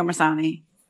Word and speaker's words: Missoni: Missoni or Missoni? – Missoni.